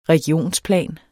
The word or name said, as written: regionsplan